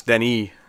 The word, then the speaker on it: Denis: That even Dennis was wrong. You know, it's Denis.